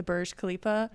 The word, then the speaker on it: Burj Khalifa: Burj Khalifa.